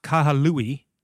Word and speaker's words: Kahului: Uh, Kahului, that's it. That's how you say it.